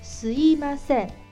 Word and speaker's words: すいません: 对，就是把第二个音的咪换成了一，原来是すみません，现在就变成了すいませんすいませんすいませんすいません。